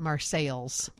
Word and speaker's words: Marseilles: Hey, how do you pronounce Marseilles? – Marseilles. – Okay, well, a lot of people who live there call it Marseilles.